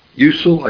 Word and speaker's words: Jusel: Usel, I guess his name is Jusel, but the J is pronounced like a Y in German. Usel,